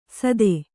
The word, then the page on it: ಸತ್ತೆ: satte